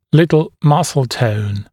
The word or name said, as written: little muscle tone